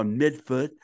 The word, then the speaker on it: Medford: Well, this past weekend I finally made it to Stereo Jacks, which is a store in the Ball Square neighborhood of Somerville, practically Medford or midfoot, how they say it over there.